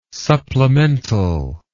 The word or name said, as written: supplemental